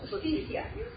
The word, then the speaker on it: justicia: Justicia. – Justicia. Because a J is like a junta.